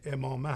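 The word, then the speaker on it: عمامه: تلفظ درست این امامه امامه هست ولی خب مردم امامه میگن ما همین امامه میخونیم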